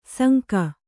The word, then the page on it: ಸಂಕ: sanka